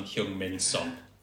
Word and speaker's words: Heung-Min Son: Why was Cresswell such a stretch, but perfect Korean pronunciation of Heung-Min Son? – Cresswell and Heung-min Son. Yeah, well done. – Said perfectly. – That's fantastic. – A perfect Korean accent.